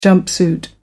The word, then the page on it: jumpsuit: American English